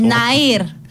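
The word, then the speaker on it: Nehir: Και 1980, Νεχίρ Ερντογάν, Τουρκάλα ηθοποιός. Δεν ξέρω αν έχει καμιά σχέση και με το. – Ναΐρ. – Τι, Νεχίρ. – Ναΐρ. – Ναΐρ. – Έτσι, προφέρετε. – Α, Ναΐρ. Οκ.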